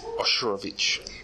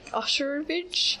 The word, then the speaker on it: Osherovich: Okay, so we have Millie, played by Kathryn Newton, the Blissfield Butcher, played by Vince Vaughn, uh, Josh, played by Misha Oshiro? – Osherovich. – Okay, Osherovich. I'm not sure how you pronounce his last name.